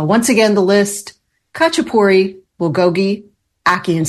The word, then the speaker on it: bulgogi: The second one is bulgogi. B-U-L-G-O-G-I, bulgogi. Number three is ackee and saltfish, A-C-K-E-E, and saltfish.